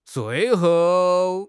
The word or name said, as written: zui4 hou4